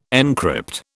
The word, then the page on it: encrypt: British English